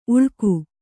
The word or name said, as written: ಉಳ್ಕು